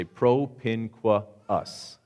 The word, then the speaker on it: propinquus: If you have 2 vowels in a row, you have to pronounce both of them. So it's super awkward. So you have to say propinquus, okay?